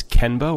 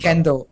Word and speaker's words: kendo: It's kenbo or kembo? – Kendo. KENDO.